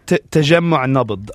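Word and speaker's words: Tajammu' Nabd: Tajja Nabda. Is that, did I say that correctly? – Tajemmu' Nabd. Tajemmu' Nabd is a group founded by an Alawite from Homs who actually goes out and tries to stop sectarianism within the protests.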